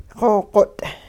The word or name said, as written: xoo-qot'